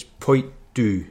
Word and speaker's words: Poit Dhubh: I think it's Poit Du. I know the Du's right, but the Poit is P-O-I-T-D-H-U-B-H, Poit.